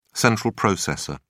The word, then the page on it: central processor: British English